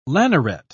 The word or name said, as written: lanneret